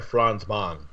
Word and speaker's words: Franzman: F-R-A-N-Z-M-A-N 86, on Reddit. So this is quoting Reddit here. – Is it Franzman or Franzman? – Franzman.